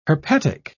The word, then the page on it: herpetic: British English